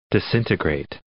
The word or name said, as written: disintegrate